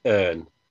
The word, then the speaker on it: Urne: That is how it's pronounced, Urne, isn't it? – Yeah, it's just pronounced slightly different, a bit more accent in Europe, okay. In the mainland, because it's like a European version of it. But over here, obviously, it just looks like urine. Disaster.